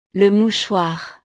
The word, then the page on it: le mouchoir: frances